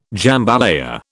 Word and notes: jambalaya: British English

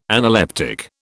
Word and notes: analeptic: British English

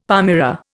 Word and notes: palmyra: Indian

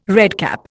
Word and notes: redcap: Indian

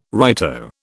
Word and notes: righto: British English

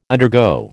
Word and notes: undergo: American English

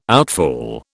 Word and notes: outfall: British English

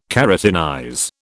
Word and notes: keratinize: British English

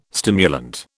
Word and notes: stimulant: British English

